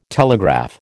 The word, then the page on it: telegraph: American English